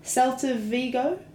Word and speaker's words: Celta Vigo: Celta Vigo? – Oh, okay.